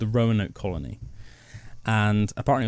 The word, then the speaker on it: Roanoke: Roanoke, no, but I love I love the name Rowan and I love oak. – Yeah, it's Roanoke colony, so it's spelled like O... Sorry, R-O-A. – Yeah. – N-O-K-E.